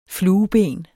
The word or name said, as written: flueben